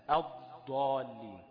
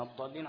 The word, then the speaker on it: الضالين: فالضاد برضو هتبقى نفس المسألة الضالين، فلازم يبقى فيه ايه يعني سنة كده، لكن الضالين على طول كده ومشددش الضاد.